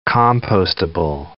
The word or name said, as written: compostable